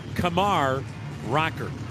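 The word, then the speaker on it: Kumar Rocker: Kamar Rocker, you spell his first name, K-U-M-A-R. You heard his name pronounced a lot of times, Kumar, but that's not right. The pitch, fisted back, foul, 1-2 the count. His preferred pronunciation is Kamar Rocker.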